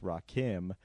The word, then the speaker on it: Rakim: So, as we all know, I'm sure, Rakim is a rapper and it's pronounced Rakim.